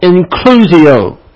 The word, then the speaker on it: inclusio: What we are looking at here in Romans chapter 2, verse 5, is what exegetes refer to as an inclusio. You may not have heard that word before. It's spelled I-N-C-L-U-S-I-O, inclusio. Here in Romans 2:5, we have an inclusio, I-N-C-L-U-S-I-O.